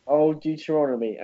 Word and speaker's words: Old Deuteronomy: Old Deuteronomy. – Old Deuteronomy.